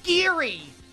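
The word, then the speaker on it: Gerry: Jerry with the G. Pronounced Geary. Geary.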